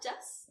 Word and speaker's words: Jess: Oh, I always feel like that always gets Courtney because every time I introduce myself, I'm just like, I'm Jess.